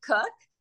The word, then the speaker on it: Koch: I pronounce it cook. Um, spelled K O C H, and it's my mother's maiden name.